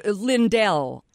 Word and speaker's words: Lindell: Is that how you pronounce it? Mr. Pillow? – Oh, Lindell, I think, yes.